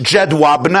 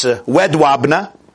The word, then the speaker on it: Jedwabne: The Soviet Union then occupied part of Poland, including the city, it's pronounced in English, Jedwabne. I believe in Polish it's Wedwabne.